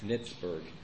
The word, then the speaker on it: Nitzberg: He said that it rhymes with Pittsburgh. It's Nitzberg. Very interesting.